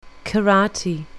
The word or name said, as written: karate